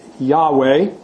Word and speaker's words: Yahweh: So we have this sequence of two semi-vowels, same a Y and a W, and two consonants, which were apparently pronounced Yahweh. It's the best guess of Hebrew scholars.